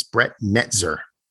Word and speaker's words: Netzer: Just like I'd like Brett Nel- Netzler to fuck off. – Okay. – Pretty much saying his name wrong, but it's Brett Netzer.